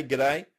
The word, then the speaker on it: G'day: Am I saying that right? Good day. So, good day. It's a bit more British. Down here, we sort of we ah. just have a GI ah. double DA, g'day. G'day.